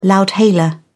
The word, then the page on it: loudhailer: British English